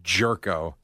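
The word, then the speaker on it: Gyorko: And I wish it was spelled like J-E-R-K. – I know. – O. – I know. Instead, it's G-Y-O-R-K-O or something. – So why wouldn't he go with a different pronunciation? – Like Yurko. – Yeah, I would go Yurko. – By the way, I'm not sure that it's not. I've never heard it pronounced any other way than Jerko. – Right, the announcers are going Jerko.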